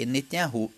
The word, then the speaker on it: Netanyahu: A pronúncia é Netanyahu e não Netanyahu.